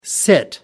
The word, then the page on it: sit in: American English